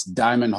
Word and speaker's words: Diamyn: My name is Diamond Hall. And when you look at my name, it's spelled like D-I-A-M-Y-N, but just think of it as Diamond without the O-N-D at the end. So it's Diamond Hall.